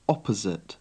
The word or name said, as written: opposite